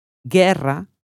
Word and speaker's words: guerra: Seguita da UE, UI si pronuncia come l'italiano GE, GI. La U non si pronuncia. Guerra, aghila.